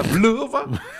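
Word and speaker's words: Vlhová: Einmal gewinnt Vlhova, Petra Vlhova. und am zweiten genau so spricht man das und aus.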